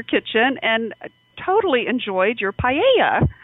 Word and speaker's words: paella: but let's just quickly chat about paella. I don't know if I'm saying that right. Paella? How do you say that correctly? – Paella.